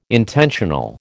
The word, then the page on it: intentional: American English